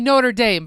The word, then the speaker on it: Notre Dame: In the United States, we say Notre Dame.